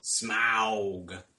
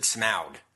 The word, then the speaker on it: Smaug: make sure they pronounce it Smaug, not Smaug.